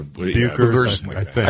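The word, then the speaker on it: Bueckers: And is it Paige Buckers? I don't want to mispronounce her name. – Bukers.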